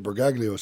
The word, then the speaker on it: Bergoglio: Not with I don't know how to pronounce the Pope's, Bergoglio, that's it, Bergoglio said, surely.